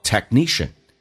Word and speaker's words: technician: 第一个听懂一个单词叫 technician，技术人员，重音是在后面，前面那个可是不用发音，叫做 technician。